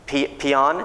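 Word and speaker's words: paean: And they said, we P-E-A-E-N, peon, it's pronounced in English.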